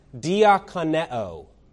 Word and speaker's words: diakoneo: That word minister in the Greek is pronounced diakoneo. Diakoneo. If you listen closely to diakoneo, you will hear the very word deacon within that word.